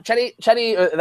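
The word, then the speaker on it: Cedi: Chetty, that's how you pronounce his name.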